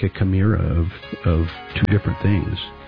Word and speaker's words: chimera: When you take two different species and you create a new creature, a chimera. Am I pronouncing that correctly? I think so. – Yes, yes, that's it.